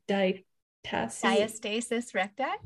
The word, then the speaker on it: diastasis recti: diastasis recti.